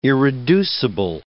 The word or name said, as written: irreducible